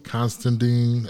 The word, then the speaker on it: Considine: Paddy Considine, I think that's how you pronounce his last name.